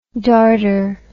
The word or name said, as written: dartrous